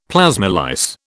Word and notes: plasmolyse: British English

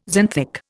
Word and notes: xanthic: Indian

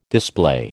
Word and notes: display: American English

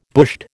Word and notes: bushed: American English

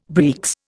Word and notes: breeks: Indian

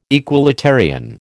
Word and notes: equalitarian: American English

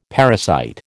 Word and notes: parasite: American English

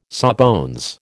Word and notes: sawbones: American English

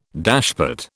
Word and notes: dashpot: British English

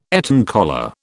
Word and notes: Eton collar: British English

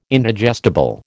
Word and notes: Indigestible: American English

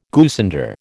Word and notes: goosander: American English

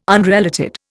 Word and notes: unrelated: Indian

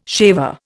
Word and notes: shaver: Indian

